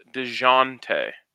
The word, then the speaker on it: Dejounte: I believe it's pronounced DeJounte. – What'd I say? – You're kind of saying a hard J, DeJounte. – DeJounte. – DeJounte. – DeJounte. DeJounte. – Like Dijon Mustard.